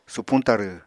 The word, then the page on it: supuñ tarw: apatani